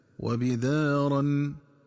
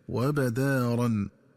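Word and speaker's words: وَبِدَارًا: وكذلك ينبغي التنبه للنطق الصحيح لكلمة وبدارا، فتقرأ بكسر الباء هكذا وبدارا، ومن الخطأ قراءتها بفتح الباء هكذا وبدارا.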